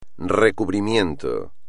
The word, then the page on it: recubrimiento: espagnol